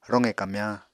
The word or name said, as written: roqe kamyañ